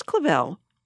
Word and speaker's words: Clavel: But Miss Clavel, which rhymes with gazelle, mm-hmm.